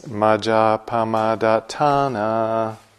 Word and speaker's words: Majjhapamadatthana: The way that Sally and I say it is Majjhapamadatthana. Normally in Pali, if there's a long A or a long accent over a vowel, that's the syllable that gets the emphasis. But it's also the case that when there's a double consonant, like the D-A-T-T, that's also an inclination to emphasis.